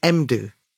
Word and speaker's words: Mdou: Although, watching the recent documentary, I found out that his name is Mdu. Mdu, rather than it's modern. Mdou Moctar.